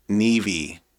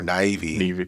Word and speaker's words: Niamh: I think it's like a Gaelic or Celtic name. So I think it's pronounced Nevy or Nivy. – Nivy.